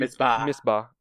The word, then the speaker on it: Misbah: Misbah. – M-I-S-B-A-H. M-I-S-B-A-H. Yeah, that said it right.